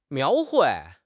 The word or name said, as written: 描绘